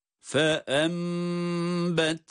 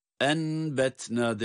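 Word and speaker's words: فَأَنْبَتْنَا: Fe embetna, enbetna değil, fe embetna diye iklab ile okunuşu bu şekilde görmüş ve tatbik etmiş oluyoruz.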